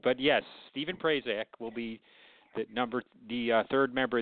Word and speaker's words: Prazak: And, of course, Stephen – how do you say his last name? I'm going to mess it up. – Prazak. – Is that right? Yeah.